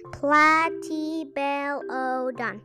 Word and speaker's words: Platybelodon: You say it like this Platybelodon.